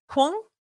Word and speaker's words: Hung: When Hung, did I say that right? Hung? – Yes.